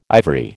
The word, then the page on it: ivory: American English